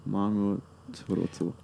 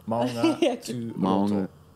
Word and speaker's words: Maungaturoto: Maungaturoto. – Maungaturoto.